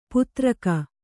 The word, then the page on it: ಪುತ್ರಕ: putraka